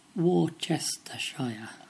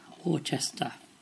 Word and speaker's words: Worcester: Well, Darlene at Twistabout on Twitter said Worcestershire. Worcester is the town in Worcestershire. And is that correct? No, it's not. That one is pronounced Worcester in Worcestershire.